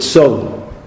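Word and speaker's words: son (צאן): Like, say, the word son, sheep, right? Sadi, aleph, nun. But you don't pronounce the aleph in any way. It's just gone.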